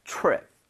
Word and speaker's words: trip: 요거도 TR 사운드. – 아하. 그러면 약간 CH처럼 yeah. 해서 입 모양을 모아서 발음해야 되겠네요. 그래서 제가 보여드리겠습니다. 두 번 드릴게요. Trip.